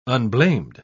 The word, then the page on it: unblamed: British English